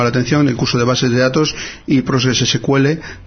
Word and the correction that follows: PostgreSQL: de Víctor Dorantes. – PostgreSQL, lo he dicho mal, pero bueno, lo repito.